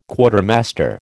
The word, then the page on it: Quartermaster: American English